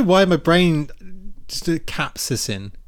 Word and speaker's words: capsaicin: I don't think I'm saying that right, but I'm going to have to Google this now. How to say capsaicin? – Capsaicin. – Yeah. Spicy bits. Right. – Capsaicin. Okay. There we go.